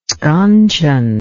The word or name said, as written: sconcheon